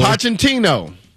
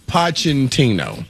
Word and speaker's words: Pochettino: All right, you're going to have to help me out with the pronunciation of your guy's last name here. Mauricio Pochettino. – Pochettino? – Pochettino.